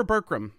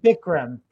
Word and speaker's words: Bikram: Is it Bikram or Burkram? How did I say it? – Bikram. Bikram yoga.